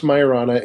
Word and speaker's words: Majorana: Yeah, it's pronounced Majorana. It's named after an Italian theorist from the 30s who unfortunately disappeared.